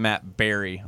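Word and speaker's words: Barrie: Apparently it's Matt Barry on Twitter, even though it's B-A-R-R-I-E.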